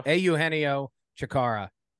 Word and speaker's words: Eugenio Chacarra: But for those of you who are unaware, Eugenio, am I saying that right? – Eugenio Chikara won the St. Andrews Bay Championship.